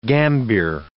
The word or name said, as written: gambir